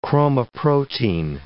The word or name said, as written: chromoprotein